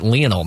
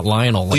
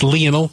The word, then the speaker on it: Lionel: I even pronounced it Lionel, not Lionel. – Yes, it's Lionel Messi. That's how the Argentinians pronounce Lionel.